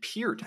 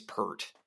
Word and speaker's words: Peart: and they pronounced it Peart. I'm like, I swear, Rush drummer guy is Pert. That's the only way I've ever heard that name pronounced.